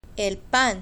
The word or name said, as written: el pan